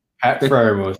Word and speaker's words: Pat Freiermuth: Pat Freiermuth. Did I say that right? – Pat Freiermuth, yep.